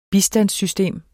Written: bistandssystem

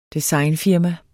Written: designfirma

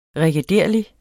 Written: regederlig

